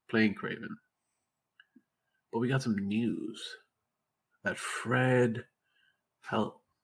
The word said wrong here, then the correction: Hechinger: I don't know how to say his name. But Fred Hechinger. There we go. Yeah, that one. Hechinger.